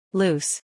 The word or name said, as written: loose